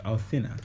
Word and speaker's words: Alsina: How's his name pronounced? Alsina. – Alsina.